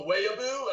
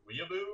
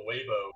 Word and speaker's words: Weeaboo: And it went on this tangent of her telling me about how much of a Waybow or a Weeaboo. I think it was Waybow is how she pronounced it.